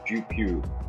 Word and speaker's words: Dupieux: So it's Quentin Dupieux. There's no X, so we don't say the X.